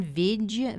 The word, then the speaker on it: Vijg: So there is a researcher, and I apologize, I don't know how to pronounce his name, Jan Vig, V-I-J-G.